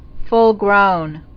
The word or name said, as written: Full-grown